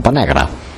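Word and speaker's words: Pa negre: Recordemos que en catalán se escribe Pan Negre y según la zona donde se pronuncia, digamos, si es por ejemplo catalán de Barcelona, el catalán más clásico es una especie de A, pero no es una A cerrada, es como Pan Negra.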